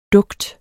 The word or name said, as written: dugt